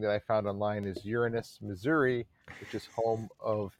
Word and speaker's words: Uranus: But the deepest one I read into is Uranus. That's right. Uranus, that's proper pronunciation.